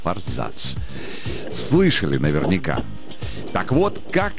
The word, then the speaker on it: форзац: Так вот, действительно, форзац считается правильным ударением. Но, вы знаете, словари сейчас спорят. Некоторые, те, что ратуют за произношение для комментаторов, для дикторов, для всех СМИ, они говорят, что форзац – это правильный вариант ответа.